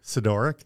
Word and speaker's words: Sidoryk: Scott, how do you say your last name? Sidoryk. Sidoryk. Yes, Scott.